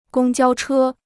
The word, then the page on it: 公交车: gōng jiāo chē